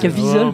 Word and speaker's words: Caviezel: Et peut-être que l'idée de prendre Cazaviel et... C'est ça comme ça que ça se prononce? – Caviezel, pardon, bon bref.